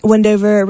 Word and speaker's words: Windhover: H is silent, strangely. But it is a beautiful publication. Not just my opinion, but from the opinion of the people I talked to last night at the, Windover reception.